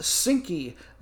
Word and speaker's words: Zhinky: First, the gals from Ukraine, or as they are known for their nickname, I believe it is pronounced Zhinky, Z-H-I-N-K-Y, which,